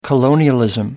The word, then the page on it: colonialism: American English